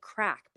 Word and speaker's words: craic: And they always say like, oh, that was good crack, but it's spelled C-R-A-I-C.